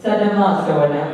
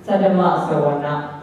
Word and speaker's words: Saddhamma Savana: The second one is Saddhamma Savana, but we can say Saddhamma Savana.